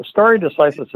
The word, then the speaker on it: stare decisis: How do you say it again? Story, stare decisis is the doctrine stare in, decisis. In, in, American law that the, the decision stands.